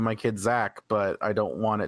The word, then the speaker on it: Xaq: My personal favorite is still, it was a kid named Zach. – Okay. – But it's spelled X-A-Q. – X-A-Q? – X-A-Q. – What the hell is that? Is it? – That's how you spell Zach.